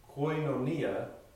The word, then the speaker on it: koinonia: Koinonia, or koinonia, I put pronunciations there on the sheet for you, because apparently it can be pronounced both ways, because when I looked it up, it gave me both pronunciation ways, koinonia, or koinonia.